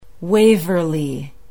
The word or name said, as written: waverly